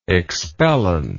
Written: expellant

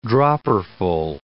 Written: dropperful

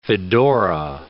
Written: fedora

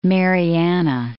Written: Mariana Islands

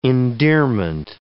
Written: endearment

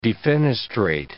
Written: defenestrate

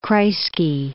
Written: Kreisky

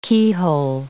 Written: keyhole

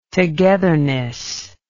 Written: togetherness

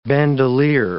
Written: bandoleer

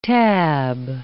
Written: tab

